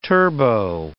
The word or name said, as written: turbo